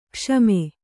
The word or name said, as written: ಕ್ಷಮ